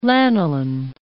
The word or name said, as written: lanolin